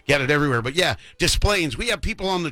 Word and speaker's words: Des Plaines: Yeah, I'm going to try to get out and see you at Des Plaines and my old stomping ground and give him some more of my money. But is that how it's pronounced, Des Plaines? – Des Plaines, yeah.